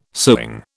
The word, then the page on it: sewing: British English